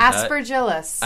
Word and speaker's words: aspergillus: And because the mold is aspergillus. – Aspergillus. We cannot guarantee that the pronunciation of aspergillus. – That. Aspergillus. – Aspergillus. It is called aspergillus.